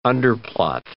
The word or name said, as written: underplot